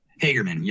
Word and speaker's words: Hagerman: Hagerman.